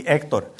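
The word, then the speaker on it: Héctor: Monse would appreciate that because H's are not pronounced in Spanish, so it would be Hector. But he's pronouncing it in English for me, you know.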